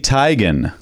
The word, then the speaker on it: Teigen: Chrissy Teigen.